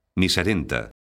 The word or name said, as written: miserento